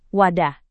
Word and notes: wadah: Indonesian

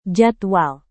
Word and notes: jadwal: indonesian

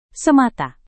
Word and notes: semata: Indonesian

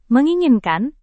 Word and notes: menginginkan: Indonesian

